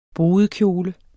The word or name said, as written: brudekjole